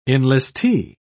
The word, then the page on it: enlistee: British English